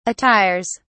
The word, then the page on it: attires: British English